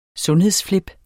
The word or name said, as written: sundhedsflip